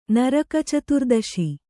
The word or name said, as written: ನರಕಚತುರ್ದಶಿ